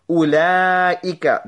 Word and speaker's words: أُولَٰئِكَ: أُولَٰئِكَ, that waw is not pronounced.